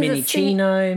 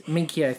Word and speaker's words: Minchio: Everybody does. Specifically if you're a doctor, doctors always pronounce it wrong every time. – Yeah, because Minichino, Minchow. Yeah, just Minchio, thank you.